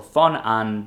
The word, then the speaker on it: von Ahn: So here's how Louis von Ahn, or von Ahn, because he pronounces the V as an F, his story begins in Guatemala City.